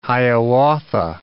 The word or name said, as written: Hiawatha